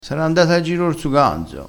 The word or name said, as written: ganzo